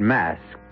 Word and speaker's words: Masque: The word mask, you should know, is spelled M-A-S-Q-U-E and means masquerade. And to begin this particular masquerade,